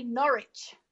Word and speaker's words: Norwich: That's funny because when I say Norwich, people laugh at me around here. And I was born in Norwich. – Yeah, well, you tell them. That's how you say it.